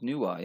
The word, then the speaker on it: Nuwa: So this is called Fuxi and Nuwa, the parents of humanity. Am I saying that right? – You are saying that right. Fuxi and Nuwa, yeah.